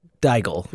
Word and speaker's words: Daigle: Oh, God, how do you say that name? Lauren Daigle? Daigle? – Daigle, let's say. – If you're struggling with it, then I had no chance. – Daigle? – Yes.